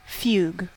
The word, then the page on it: fugue: American English